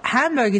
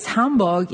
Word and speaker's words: Hamburg: How do you say Hamburg? – Well, Hamburg is what you say if you're an English speaker who is not from Germany. I guess Hamburg is more what you say if you live here and you talk about it here.